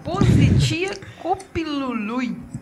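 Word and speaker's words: Poziția Copilului: Positia Copilului.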